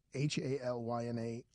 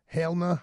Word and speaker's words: Halyna: Hey, I believe it's Halyna Hutchins. I'm sorry. Halyna? How do you say it? Halyna Hutchins.